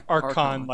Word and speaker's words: Archon: Archon, like K.